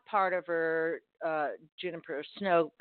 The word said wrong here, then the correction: Juniper Song: I'm saying it right, am I, Steph? That Juniper Song. – Juniper Song, okay, sorry.